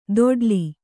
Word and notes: ದೊಡ್ಲಿ: doḍḍli

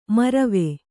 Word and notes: ಮೌರ್ವ: maurva

